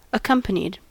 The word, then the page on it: accompanied: American English